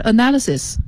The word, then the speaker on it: analysis: 再往下是 analysis，这个词不好念。 – Analysis，你看啊，它是 analyze 的一个名词形式，但它读音跟 analyze 不一样，重音也不一样，后面那个 lies 和这里的 analysis 也是不一样的哈。